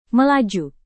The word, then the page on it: melaju: Indonesian